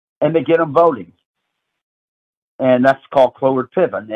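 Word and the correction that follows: Cloward-Piven: And or uh, Cloward Piven, as some people pronounce it.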